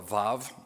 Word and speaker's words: vav: You can't see it in English, but you can see it in Hebrew because he takes a coordinating conjunction called a vav. It's pronounced V-A-V, but it's really W-A-W.